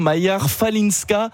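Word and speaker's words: Maja Chwalińska: La Polonaise, vous allez me dire si je le prononce bien, Pierre Maja Kwalińska.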